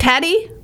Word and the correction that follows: Tady: It's actually Tady. Rhymes with lady, yes. – Tady.